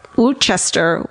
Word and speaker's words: Worcester: Also, turns out Worcester is nuts.